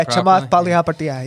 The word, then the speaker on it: Chamath Palihapitiya: Yeah, Chamath Palihapitiya.